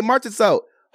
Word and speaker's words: Marchessault: Oh, I'm saying this wrong. It's so hard to say his last name. How you say it? Okay, Marchessault. Host a meet and greet in Henderson. Jonathan Marchessault will stop at the Dick's Sporting Goods at the Galleria at Sunset Mall on today.